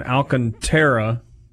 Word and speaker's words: Alcantara: And then Alcantara, did I say that right? – Nailed it.